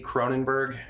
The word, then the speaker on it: Cronenberg: Cronenberg, and I will spell that for you.